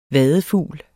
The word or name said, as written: vadefugl